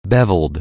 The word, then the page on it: beveled: anglais